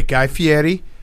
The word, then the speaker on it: Guy Fieri: It is Guy Fieri. I screwed up and I did the American pronunciation of his name. You gave me a look. – We corrected it. – Right away. Guy Fieri.